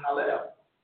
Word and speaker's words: Hallel: I've shared this many times, but in the original Hebrew, the name for Lucifer there, it should have been, in Hebrew, it's pronounced Hallel. Okay?